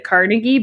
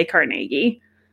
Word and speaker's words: Carnegie: And everyone else outside of Pittsburgh pronounces it Carnegie, but we all say Carnegie.